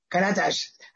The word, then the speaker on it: Karatas: And my guest today is Sudi Karatas. I, don't know why I want to say Saudi, but Sudi. Well, but, yeah, but you pronounced my last name excellent. That was perfect, actually.